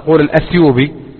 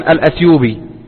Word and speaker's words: الأتيوبي: ومن طرائف ما يروى أن شيخنا الشيخ العلامة محمد بن علي آدم الأتيوبي الأتيوبي يكتب في كتاباته وفي كتبه بالتاء، بينما السائد في النطق أن الناس يقولون الأثيوبي له ولغيره. فحصل أن أحد طلبة العلم مر عليه فلما سلم عليه فنطق كان يعرف الشيخ محمد علي آدم الأثيوبي، قال الأثيوبي، قال لكن نحن يا شيخ يعني ننطقها هكذا منذ ولدنا. قال أنتم منذ ولدتم وأنتم مخطئون، لماذا؟